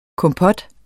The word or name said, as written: KOMPOT